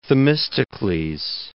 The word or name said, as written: Themistocles